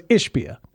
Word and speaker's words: Ishbia: All right, so Matt Ishbia, and I got to stop putting the emphasis on the second syllable. I got to stop doing that. – Ishbia? – I do that too much. Matt Ishbia. So Matt Ishbia.